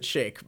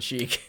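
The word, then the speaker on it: sheikh: Like, I mean, in the real world, you pronounce it shake, but. – Oh! Okay. – Like, for a long time in America, we didn't know that, and they said chic.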